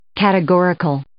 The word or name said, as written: categorical